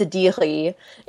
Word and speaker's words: Sedighi: So my username is Saba, S-A-B-A, and my last name, which is S-E-D-I-G-H-I, and that is pronounced Sidiri.